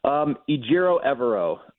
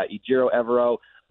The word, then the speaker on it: Ejiro Evero: Ejiro Evero is sort of how we've come to know that his name is pronounced.